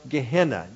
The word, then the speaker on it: Gehenna: But it comes from a Greek word, G-E-E-N-A. In the English, it is pronounced Gehenna, G-E-H-E-N-N-A, Gehenna.